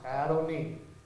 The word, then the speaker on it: Adonai: L-O-R-D. So the second Lord in Psalms 110 is Adonai. This is pronounced Adonai.